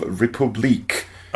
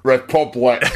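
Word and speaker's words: République: This week, I have been playing a video game that goes by the name of Republique. – I wondered how you were going to pronounce it. – Republic. – You're going to go for the affected French. Republique.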